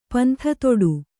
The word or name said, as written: ಪಂಥತೊಡು